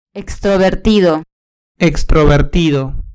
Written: extrovertido

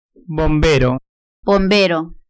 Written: bombero

